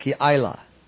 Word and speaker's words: Keilah: That's how you say it, Keilah.